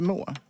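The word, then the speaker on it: Meaux: Är det meux? Är det inte må? – Må uttalas det såklart.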